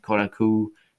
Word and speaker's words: Kotaku: Kotaku, yeah, that's how you pronounce it, the Los Angeles Times, New York Times, Time.